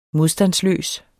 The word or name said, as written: modstandsløs